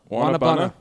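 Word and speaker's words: guanábana: also, I definitely had a blast listening to y'all try to pronounce Guanabana. It's pronounced Wana Wanabana. Wanabana. Wanabana.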